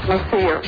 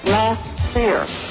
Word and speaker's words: Messier: Lou Ann Messier. – What's your name? – Lou Ann Messier. – You always say it like that? Lou Ann Messier. – Messier. – What? – Messier. It's French.